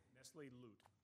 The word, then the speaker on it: Lauti: Nestle Lauti. I'm not sure I've got that pronunciation. Did I get that okay? – Nestle Lute. – Lute, thank you.